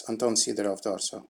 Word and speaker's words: Anton Sidoroff-Dorso: Yes, Anton Sidoroff-Dorso.